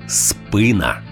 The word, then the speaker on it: спина: Всі словники єдині в думці, що українською правильно казати «спина».